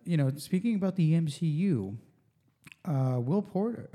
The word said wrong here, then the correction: Poulter: uh, Will, how do you say it? Poulter, the the eyebrows guy from, um, the, you know, the British uh, guy with the eyebrows.